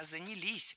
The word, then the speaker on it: занялись: Не занялись, а занялись. Ударение ставим все туда же, на последний слог, рядышком с частью «с».